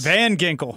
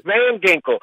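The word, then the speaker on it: Van Ginkel: And so Van Ginkle, he has a – and am I saying his name right? Is it Ginkle? – It is. – Van Ginkle. – Yes, you got it. – Van Ginkle.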